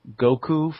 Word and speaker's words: Goku: Number two, Goku from Dragon Ball.